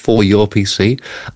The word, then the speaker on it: 4URPC: It's the 4URPC, pronounced 4URPC, and I think it looks like a jolly good thing.